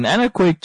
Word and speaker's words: anechoic: Now, an anechoic chamber, which if you pronounce it is A-N-E-choic, so it's C-H-O-I-C, means non-reflective or without echo.